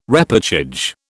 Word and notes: repêchage: British English

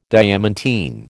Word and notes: diamantine: American English

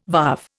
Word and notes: varve: Indian

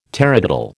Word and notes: tarradiddle: American English

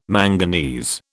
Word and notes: manganese: British English

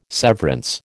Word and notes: severance: American English

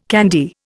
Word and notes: candy: Indian

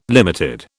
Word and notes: limited: British English

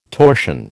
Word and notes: torsion: American English

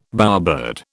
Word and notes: bowerbird: British English

